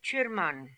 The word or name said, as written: Chirman